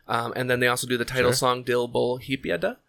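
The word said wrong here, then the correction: Hadippa: So I definitely said that wrong. Hadipa. There we go.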